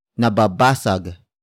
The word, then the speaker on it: nababasag: Nababasag.